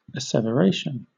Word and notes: asseveration: British English

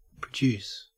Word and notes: produce: Australian English

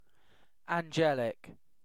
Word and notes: angelic: British English

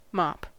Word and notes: mop: American English

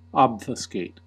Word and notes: obfuscate: American English

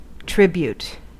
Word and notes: tribute: American English

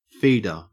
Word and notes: feeder: Australian English

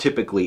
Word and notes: typically: American English

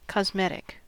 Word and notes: cosmetic: American English

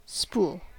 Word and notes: spool: American English

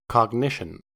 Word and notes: cognition: American English

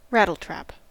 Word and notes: rattletrap: American English

